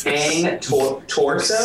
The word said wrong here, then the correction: Cane Corso: Cane corso.